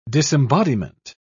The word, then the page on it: disembodiment: British English